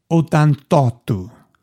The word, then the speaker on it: Ottantotto: Ottantuno. You know, I'm sorry, I said it wrong. Ottantotto. Yeah, I completely messed it up.